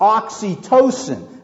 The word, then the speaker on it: oxytocin: And here's what's interesting about oxytocin. I don't know if I'm saying it correctly. It's spelled O-X-Y-T-O-C-I-N. It's not Oxycontin, okay? It's not that bad drug, okay? It's oxytocin.